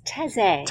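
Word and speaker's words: Taizé: I'm not saying it right. – It's Taze. – Taze. Taze. Yes. T-A-I-Z-E. – Taze. With an accent aigu on the E.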